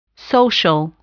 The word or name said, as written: social